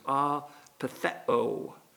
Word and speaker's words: apeitheo: And that word is apetheo. A-P-E-I-T-H-E-O with a long O there.